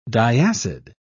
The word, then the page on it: diacid: British English